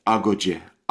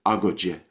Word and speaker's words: Agodjie: A-G-O-D-J-I-E. How should one pronounce that? – So, to my memory, it should be in Fong. Agodjie. Agodjie. We're very tempted as Westerners to throw a stress in, but it should not have a stress. Agodjie.